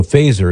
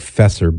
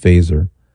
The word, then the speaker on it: Feser: So Fazer, his name is spelled F-E-S-E-R. It looks like Fesser, but it's pronounced Fazer, as in set your phasers to stun.